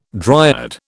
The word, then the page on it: dryad: British English